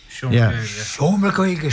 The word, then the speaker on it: Seomra Gaeilge: Seomra Gaeilge, is that how you pronounce it? – Seomra Gaeilge. Yeah, absolutely.